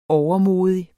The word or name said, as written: overmodig